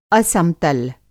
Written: असम-तल

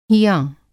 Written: हियाँ

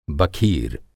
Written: बखीर

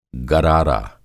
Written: गरारा

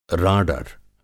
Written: राडर

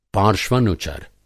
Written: पार्श्वनुचर

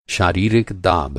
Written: दाब

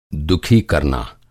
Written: दुःखी

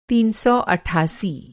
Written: तीन सौ अट्ठासी

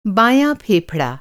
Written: फेफड़ा

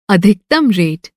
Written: अधिकतम

